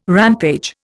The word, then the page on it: rampage: Indian